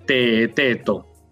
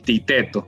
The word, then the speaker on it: Teeteto: Se pronuncia así porque está escrito con dos es, T, doble E, otra T, otra E, otra T, una O y una S, ¿no? Teeteto. Y a veces también pronunciado el Titeto, por lo mismo, ¿no? Por esta forma anglosajona de pronunciar I a la doble E.